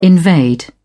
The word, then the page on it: invade: British English